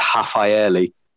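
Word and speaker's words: Rafaelle: It's if you want to do it like properly in Portuguese, it's Rafaeli. In Portuguese, okay. If something begins with an R, you pronounce it like as an H.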